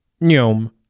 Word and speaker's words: NEOM: It's pronounced like The Matrix's Neo plus an M, and if you say it fast enough, it almost sounds like a NASCAR whizzing past you at Talladega. NEOM.